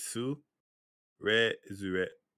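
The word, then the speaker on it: Tsurezure: Yeah, because it's the first part is su, re, zure, kind of like that.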